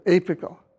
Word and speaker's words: apical: A-P-E-K-S. And for apical, I'm going to spell the letter before the final syllable phonetically. It's pronounced as a K. So apical.